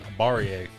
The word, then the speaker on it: Barrier: I can't say his name. Bel Air, I think is his name. – I don't know. As much as I would love to choose Montana, I think Montana, like your pick with the UT Martin, Montana's going to win the game, but I'm going to Eastern Washington. – Okay. I respect that. – His name is Barrier. – Barrier, I wasn't even close.